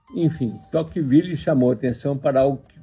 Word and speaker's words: Tocqueville: Seu nome era Alex de Tocqueville, acho que a pronúncia é essa mesmo.